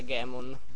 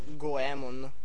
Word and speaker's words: Goemon: Anzi, una cosa importante: c'è Ultraman che mi ha detto che Gammon non si pronuncia Gammon, ma ha detto che in giapponese si legge come si scrive, ovvero Goemon.